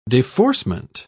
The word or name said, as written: deforcement